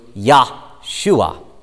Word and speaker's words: Yahshua: Jesus is the Greek form of the Hebrew name Joshua, pronounced in the Hebrew Yahshua, Yahshua, which literally means Yahweh saves.